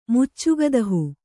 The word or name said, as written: ಮುಚ್ಚುಗದಹು